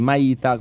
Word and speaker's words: Maita: La cosa forte è che noi abbiamo questa, così dice, questo brevetto, perché se tu vai su Google, qualsiasi motore di ricerca, scrivi Maita con l'accento sulla I, ci siamo solo noi.